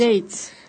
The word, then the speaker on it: Yates: Yates.